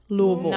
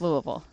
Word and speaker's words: Louisville: Did I say that right? Louisville. – Not quite. It's Louisville.